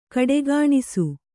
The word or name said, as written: ಖೋಡಿಗಾಣಿಸು